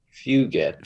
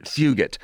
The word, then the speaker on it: Fugit: Is it Fugat? – I think it's Fugit. – Fugit.